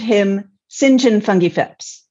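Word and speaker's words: Fotheringay-Phipps: But in British English, it's pronounced Fungi Phipps.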